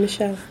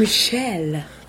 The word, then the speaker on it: Michel: That's right, Yvonne Michel. – There's only one way to pronounce Michel. Why am I struggling with the name Michel? – Yvonne Michel. – Yes, it's more like a French it is, because both of my names are French, yeah.